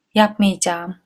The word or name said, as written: yapmiycam